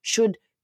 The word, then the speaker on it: should: C'est le would, should, could. Ça s'écrit W-O-U-L-D, après ça S-H-O-U-L-D, après ça C-O-U-L-D, mais on ne prononce pas le L.